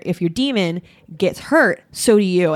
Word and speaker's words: daemon: It's pronounced demon though. – It is pronounced demon. Well, I always said daemon because I thought it was like that.